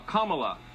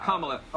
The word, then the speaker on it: Kamala: that will serve you and your fellow um, hosts on Fox, her name is pronounced comma like the punctuation mark la, Kamala. Okay?